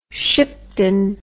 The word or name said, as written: shipton